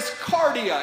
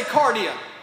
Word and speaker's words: kardia: That word there is kardia. Everybody say kardia. Of, course, it comes from and based off of um, the word that we get cardiac from. Cardiac. It's, it's spelled with a K, K A R D I A, kardia.